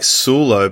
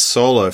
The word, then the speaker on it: Soulo: It looks like Soulo, but it's pronounced Solo for your surname.